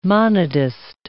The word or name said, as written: monodist